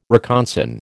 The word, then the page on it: reconsign: American English